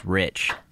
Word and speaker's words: Reich: I know someone with that last name, and it's pronounced Rich. R-E-I-C-H.